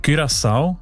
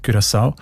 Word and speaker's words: Curaçao: Curaçao heißt das Land, habe ich gelernt. Spricht man eben mit «ü» aus, weil Holländisch dort auch eine wichtige Sprache ist. Und im Holländischen ist das «ü» offenbar häufig als «ü», Curaçao.